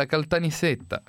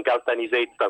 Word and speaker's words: Caltanissetta: Perché, chissà perché, intanto complimenti perché lei ha detto bene, ha detto Caltanissetta e non Caltanisetta come dicono i nostri amici del nord.